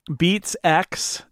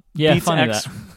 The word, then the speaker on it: Beats X: And it's what you're looking for is Beats X, not pronounced 10, apparently. – Yeah, Beats X,